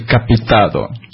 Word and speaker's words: capitada: C'est capitada, C-A-P-I-T-A-D-A. Point Escambiar. – J'insiste, on dit capitado. – Mais ça s'écrit capitada. – Ça, ce sont les subtilités de la langue occitane.